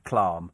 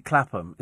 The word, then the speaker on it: Clapham: I've never pronounced it Clam. It is and always will be Clapham.